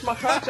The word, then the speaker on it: Machac: He can get to number one in the world, Martina, if he wins the title here, facing Tomas Machac of the Czech Republic. Did I say that right? – Close enough. Tomas Machac.